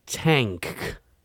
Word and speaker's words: Tangk: I-D-L-E-S. – And they just put out a new album called Tank. It's like not, it's spelled T-A-N-G-K.